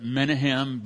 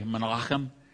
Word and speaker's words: Menachem: It looks like you would pronounce it Menahem, but it's actually Menachem. Is how you would say it in Hebrew, like Menachem Begin, former prime minister.